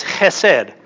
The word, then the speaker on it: chesed: The Hebrew word for steadfast love is chesed. And no, I don't have anything in my throat. It's just, it's how you say it.